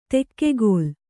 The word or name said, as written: ತುಗ್ಗಿಲ್